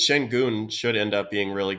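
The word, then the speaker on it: Şengün: I was trying to buy up some Alperen Shengun. – Is that how you pronounce that last name? – Yeah. – Shengun? – Yeah. – Yeah. – Oh, okay. I've always been saying Sengun.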